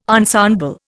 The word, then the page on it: ensemble: Indian